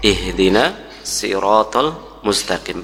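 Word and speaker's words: ihdina siratal mustaqim (اهدنا الصراط المستقيم): Ya, dari cara baca di penjelasan para ulama, ayat ini memiliki dua cara baca. Bisa dengan huruf sad, ini yang kita jumpai di mushaf Utsmani, ihdina siratal mustaqim, pakai sad ya. Yang kedua bisa pula dengan huruf sin, sin kecil, ihdina siratal mustaqim ya. Dan kedua-duanya benar ya, dibolehkan artinya ya, dibolehkan.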